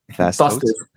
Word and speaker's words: Vaz Tostes: Vaz Tostes.